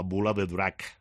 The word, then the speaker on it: Bola de Drac: En catalán era La Bola de Drac.